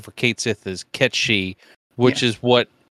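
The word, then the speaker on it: Cait Sith: So Square just casually dropped it on their some stream or a Twitter feed or whatever that it will be pronounced K Kate Sith, which in Final Fantasy VII Rebirth.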